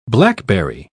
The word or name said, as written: blackberry